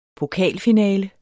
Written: pokalfinale

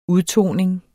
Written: udtoning

